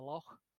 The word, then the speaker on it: loch: You get a lot of that in Scotland, where they have, rather than a lake, they have a loch. – Mm-hmm. – It's kind of at the back of your. Back of your throat, you pronounce this sort of.